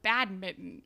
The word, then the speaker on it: badminton: Nobody has a school sport of badminton. – No. And in America, they're heavily pronouncing that N.